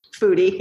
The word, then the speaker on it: Foudy: I'm pronouncing that right? – Foudy, yeah. Foudy.